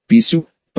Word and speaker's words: Писю: «Писю», — протянула она, с ударением на последний слог.